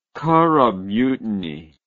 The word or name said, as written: curragh mutiny